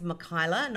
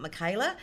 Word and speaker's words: Mikyla: People say my name wrong. It's Michaela, not Michaela.